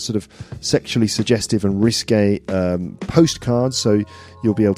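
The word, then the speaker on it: risqué: It's like risky, but sort of like pronounced as in the French way, risque.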